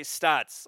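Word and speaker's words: Staats: So I say Stats.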